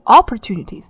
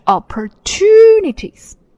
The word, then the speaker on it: opportunities: 这个单词很长，因为它是一个多音节的单词啊，所以我们要念一下 O-P-P-O-R-T-U-N-I-T-R-E-S, opportunities, opportunities, 重音在 to, opportunities, 啊。像多音节的单词啊，很多在我的课堂上面，大家很多基础弱的同学也念不好，因为他们不太懂重音，所以一定要看着音标，重读符号在什么地方，你就是要重读，不是念成 opportunities, 而是 opportunities.